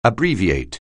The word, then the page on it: abbreviate: anglais